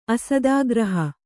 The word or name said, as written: ಅಸ್ತಗೃಹ